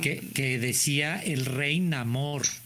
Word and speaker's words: Namor: Yo siempre le decía Namor, en inglés es Neymor y acá le pusieron Namor.